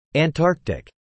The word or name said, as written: Antarctic